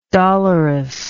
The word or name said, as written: dolorousness